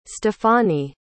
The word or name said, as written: stefani